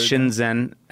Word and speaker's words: Shinzen: Shinzen, S-H-I-Z-E-N. – Zed, Canadian, sorry. – Z-E-N, Shinzen Young.